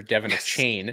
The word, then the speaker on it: Achane: That was the correct pronunciation for Devin Achane.